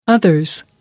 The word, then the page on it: others: British English